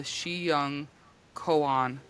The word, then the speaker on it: Xiangkhoang: That word is spelled very insanely. It has an X in it. – Oh my God. – But I looked it up and that's how you say it. The spelling is X-I-A-N-G-K-H-O-A-N-G.